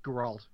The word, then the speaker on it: Geralt: Isn't it pronounced like Geralt or some shit? – Is it? I thought it was Gerald, isn't it? – Geralt.